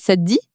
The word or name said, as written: ca te dit